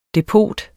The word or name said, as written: depot